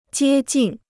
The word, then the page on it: 接近: jiē jìn